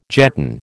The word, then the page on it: jetton: American English